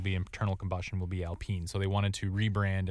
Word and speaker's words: Alpine: Is it Alpine or Alpine? – Alpine. – Alpine. Which is the same word. It's just the French pronunciation.